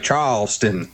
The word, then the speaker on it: Charleston: Actually, there's a little less emphasis on the R. It's more like Charleston.